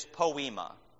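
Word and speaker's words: poiema: All right, the Greek word, I think the way you pronounce it, the God's workmanship, is poema. What does poema sound like? It sounds like the word poem.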